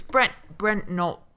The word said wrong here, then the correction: Brent Knopf: I can't say his name. Brent Knopf. Because he's like Menomina's very much like that.